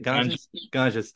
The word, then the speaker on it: Ganges: Ganges.